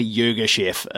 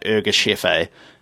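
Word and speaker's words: Yirgacheffe: So more commonly, Urgeschef is how that one's said, but I think the closest approximation we could find to the proper pronunciation of it is like Urgeschefe.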